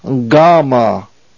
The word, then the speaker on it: gamma: Again, it's pronounced gamma. It is the G sound, as in got, g.